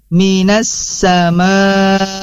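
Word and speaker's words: minas samai (من السماء): Kemudian minas samai, dari lafad minasa. Nah, di sini hati-hati karena memang sudah ada tanda tasydidnya, jangan sampai keliru bacaannya minal sama.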